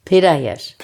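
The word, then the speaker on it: Piedayesh: Wir sitzen in Berlin im Atelier von Leila Pedesch? – Pederjesch. – Pedesch. – Wie Peter Hirsch, nur ganz zart ausgesprochen.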